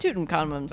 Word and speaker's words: Tutankhamun: Tutankhamun. I don't know how you say it. – Tutankhamun.